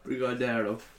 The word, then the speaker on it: Brigadeiro: the mince pie is better than the Brigadeiro. – Brigadeiro.